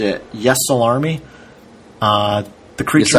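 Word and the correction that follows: Ysalamiri: I always pronounce it like Yesalamari. – Yesalamari. That sounds more accurate. Yeah, I'm looking at the spelling. That looks correct. I always used to say Yesalami.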